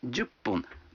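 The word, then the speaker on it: джупун (じゅっぷん): А 10 минут – джупун. Вы заметили, что когда фун добавляется к 10, дю, то произносится пун.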